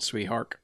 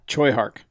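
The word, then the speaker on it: Tsui Hark: I think it's actually Choi. I know it doesn't look like that at all, but I'm pretty sure that's the proper way. – Choi Hark.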